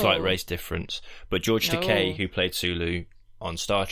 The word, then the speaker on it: Takei: I'm pretty sure it's Takei because... – It is Takei, to rhyme with toupee.